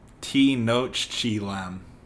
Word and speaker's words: Tenochtitlan: It's like Tenochtitlan. – Nah, I remember. There's some sh in there. I think Tenochtitlan. I don't remember how to say it. – It's pronounced Tenochtitlan.